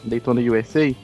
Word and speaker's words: Daytona USA: Peraí, peraí, vocês estão pronunciando, vocês estão pronunciando errado. – Qual é que é? – É Daytonausa. – Daytonausa. – Isso.